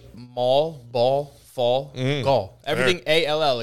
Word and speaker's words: Gaul: A lot of the other places, Gaul, kind of didn't. – Which is how my name is pronounced, but not spelt. – Gal. – G-A-U-L.